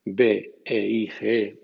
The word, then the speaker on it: beige: Aunque no es de esta tercera regla de pronunciación, b e i g e, beige, entró en el inglés desde el francés hace 150 años con la pronunciación francesa.